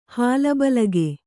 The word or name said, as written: ಹಾಲಬಲಿಗೆ